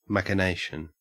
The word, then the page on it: machination: Australian English